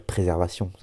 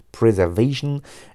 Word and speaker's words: Preservation: Mais je peux pas dire préservation, ça fait vraiment en français, ça fait con, quoi. Preservation,